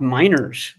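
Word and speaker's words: Meinerz: That right guard, Quinn Mariners, whatever, he looked fantastic in the one-on-one drills. Just fantastic. I think he's a pro bowler, right? – His last name is pronounced Miners.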